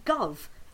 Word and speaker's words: Gove: They keep saying Michael Gove's name is Michael Gove. It's Gove. It's spelled Gove.